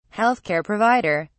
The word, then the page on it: health care provider: British English